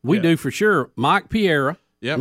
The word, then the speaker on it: Pereira: Pereira. – Think about pa, and then the way we like steak, rare. – Pereira.